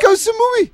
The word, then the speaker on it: Ko Samui: Ko Samui. – Too lateral for me. – What are you talking about? – The island. K-O-S-A-M-U-I.